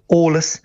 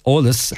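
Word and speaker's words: eolas: So if you could have it into us by the end of the week, Olus at isfeidirlinn.ie. – Okay, I'm just going to spell that for listeners. It's E-O-L-A-S at isfeidirlinn.ie. That's Olus at isfeidirlinn.ie.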